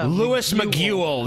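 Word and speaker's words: Luis Miguel: Wait, does that mean I have Miguel? Miguel? Louis Miguel. That's his. That's how it's pronounced, man.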